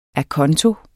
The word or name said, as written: ACONTO